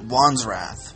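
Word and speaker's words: Wanstrath: TP Dubs, Chris Faint, Wandsrath. – He pronounces it Wandsrath.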